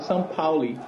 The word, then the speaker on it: Pauli: Não, não, no alemão é, é, é fácil, é se lê como, assim, você, você lê como se escreve, então é, a pronúncia é bem parecida com, com o português, então São Pauli.